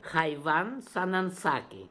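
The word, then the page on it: Khaivansanansaks: Russian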